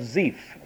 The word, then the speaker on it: Ziph: And David abode in the wilderness in strongholds and remained in a mountain in the wilderness of Ziph. That's how that's pronounced.